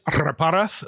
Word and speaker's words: Reparaz: Reparaz is fine. Yeah. – It's fantastic. – Technically, it's Reparaz, but even I have trouble saying that.